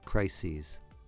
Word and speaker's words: crises: In the wake of different crises. And hear the pronunciation of crises? Well, crises, of course, is the plural of crisis. Crisis, crises.